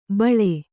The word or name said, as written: ಬಳಿ